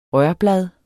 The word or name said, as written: rørblad